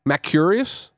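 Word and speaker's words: macrurous: Carcinization consists essentially in a reduction of the abdomen of a macrous. How do you say that word? – Oh, you're gonna ask Greg Miller. A reduction of the macrurus crustacean. – This is an R after the C.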